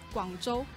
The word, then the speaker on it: Guangzhou: Guangzhou.